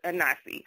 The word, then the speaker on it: Inasi: And we saw Inasi, and we was like, the name, the wording, we wanted to make it phonetically, correct. So we came up with Inasi, Inasi.